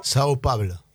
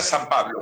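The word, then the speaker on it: São Paulo: O sea, ahí Fabián, no quiero escuchar que diga San Pablo.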